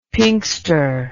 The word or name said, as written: pinkster